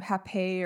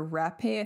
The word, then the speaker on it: rapé: I guess it's actually Hapé or Rapé. I think it's spelled more like Rapé, but yeah, okay, yeah. Cause you can spell it H-A-P-E or R-A-P-E, but it's kind of got like a little like line above the E on the end. But that makes the A sound.